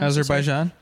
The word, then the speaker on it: Azerbaijan: And then poor Argentina, Australia, and Azerbaijan. I don't know how to say it. – Azerbaijan? – Yeah, there you go.